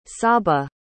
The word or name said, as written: csaba